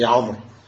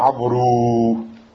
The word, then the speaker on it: Amr: Seulement, ce waou, on ne le prononce pas. Tu dis pas Amrou.